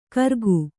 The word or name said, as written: ಕರಗು